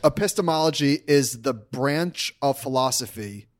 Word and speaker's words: Epistemology: Epistemology. – Did I say that right? Sounds right.